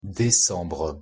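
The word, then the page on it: décembre: French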